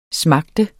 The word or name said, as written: smager